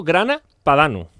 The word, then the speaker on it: grana padano: 3 cucharadas de queso grana padano.